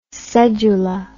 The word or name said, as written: cedula